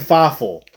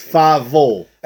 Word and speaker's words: Feivel: You're putting an F on it. You're calling him Feivel. It's Feivel. L.